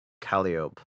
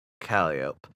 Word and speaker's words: Calliope: Calliope. I didn't pronounce that right. Calliope, for nothing to restrict him from his onslaughts.